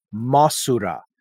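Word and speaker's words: Mosura: And then Mothra is Titanus Masura, which is the Japanese pronunciation.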